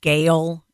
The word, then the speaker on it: Gayle: As Matt has said, my first name is Gayle. It is spelled G-A-Y-L-E.